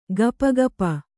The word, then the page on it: ಗಪಗಪ: gapa gapa